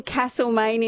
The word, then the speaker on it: Castlemaine: Pronouncing Castlemaine incorrectly as Castlemaine. – There's no R in Castlemaine.